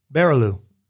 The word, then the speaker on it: Barrilleaux: F-O-R-T-I-E-R. – Fortier. – Very close. Forte. But I would give you that one. B A R R I L L E A U X. – Easy peasy. – Barilo. – What, say it again? – Barilo. – That's right. – You said it right the second time. You said Barilo the first time.